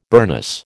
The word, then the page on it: burnous: American English